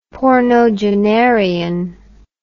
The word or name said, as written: pornogenarian